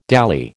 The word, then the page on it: dally: American English